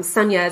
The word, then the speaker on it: Sunia: Like, I don't, you know, my name, Sunia,